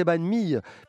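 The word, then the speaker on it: bánh mì: On comprend mieux quand on sait que banh mi, on devrait finalement euh, le prononcer banh mi, puisque ça veut dire pain de mie en fait.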